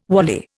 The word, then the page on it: wally: Indian